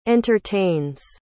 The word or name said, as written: entertains